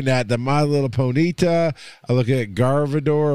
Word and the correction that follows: Gardevoir: or whatever her name is. I never say it right, but I know who that is. – Gardevoir. – Yeah. It's like God of War, but Gardevoir.